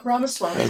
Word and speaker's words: Ramaswamy: Am I saying it right? – Yeah, Ramaswamy.